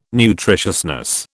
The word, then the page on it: nutritiousness: British English